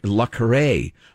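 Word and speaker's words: le Carré: Actually, the author John Le Carre, how do you say that? Is that how you pronounce it? – I always thought it was Le Carre, but I'd never heard it pronounced. – Yes, yeah, Le Carre, which is a pen name anyway, so call him anything you want.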